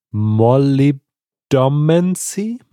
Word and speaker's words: molybdomancy: Molly, like the name Molly with one L. – Yeah. – B domancy, molybdomancy.